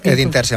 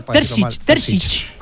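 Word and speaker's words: Edin Terzić: Bueno, el Athletic se ha puesto a entrenar esta mañana por primera vez a las órdenes de Edin Terzic, con ese largo staff que ya les hemos contado un montón de veces. – Bien pronunció. – Edin Terzic, hombre, después de preguntárselo ayer. – No, Terzic.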